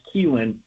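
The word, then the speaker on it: Kilen: Joshua Gonzalez, Luis Hernandez, Johnny Leavell, who's playing in San Jose, and Gavin, I believe the last name is spelled K-I-L-E-N. I believe it's pronounced Keelan, the Giants' first-round pick last year from the University of Tennessee, who played for Tony Vitello.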